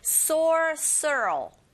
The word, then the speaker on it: sorcerer: Sorcerer. 발음이 조금 어려우시죠? R이 여러 번 들어가 있기 때문에 sorcerer 이렇게 발음하셔야 돼요.